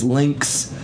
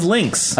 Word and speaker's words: Links: Lynx? Banagher. Banagher? – That's a little bit closer. – Last name is Lynx. – It is Lynx. – L-I-N-K-S. Yeah.